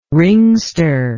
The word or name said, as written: ringster